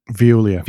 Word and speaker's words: Veolia: Veolia.